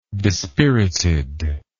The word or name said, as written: dispiritedness